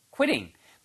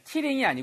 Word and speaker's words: quitting: quitting. 발음이 Q 발음이요. Q, Q, qui, qui 이렇게 발음하셔야 돼요. killing이 아니고 qui, qui. 입술이 쭉 나왔다가 군무원처럼 like goldfish. 그죠?